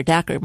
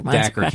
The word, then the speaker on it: Dacher: He's not been on the show, Dacher Keltner, Dr. Keltner. I don't know how you pronounce his name. But It rhymes with cracker, Dacher. – Dacher Keltner.